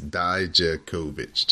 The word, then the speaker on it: Dijakovic: I thought the emphasis went on a different syllable. – No, it's Dijakovic, just like it's kind of phonetically laid out.